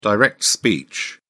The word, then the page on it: direct speech: British English